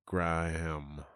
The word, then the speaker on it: Graham: Like the Graham. – Yeah, Graham. – Yeah. – And like the way you say it, it's one syllable.